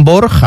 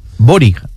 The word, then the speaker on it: Borg: Bueno, pues toda la vida diciendo Bjorn Borg, a mí que me encantaba como jugador de tenis, que realmente es como se escribe en español, y era Bori, ¿no? Borig. – Borig. – Sí, siempre lo ha dicho bien. Cuando ha hablado de Tobias Borig.